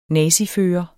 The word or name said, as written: nazitysk